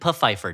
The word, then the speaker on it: Pfeiffer: Ooh, so, Desmond Pfeiffer, Dan. It's pronounced Pfeiffer. – Is it pronounced Pfeiffer? – What an interestingly misbegotten show that was.